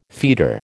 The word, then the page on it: feeder: American English